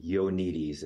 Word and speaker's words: Ioannidis: Ioannidis is how you say his last name.